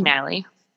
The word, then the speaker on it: Kinnally: Kinnally.